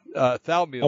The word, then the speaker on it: Thaumiel: Thamiel? That sounds like fentanyl. – Thumiel. Thaumiel. – Oh, Thumiel. Oh, okay. – It's spelled Thaumiel.